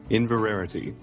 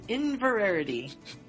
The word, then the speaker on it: Inverarity: And we are finishing Hogwarts House is Divided tonight by Inverity. – Inverarity. – Inverarity. – So you just missed one syllable.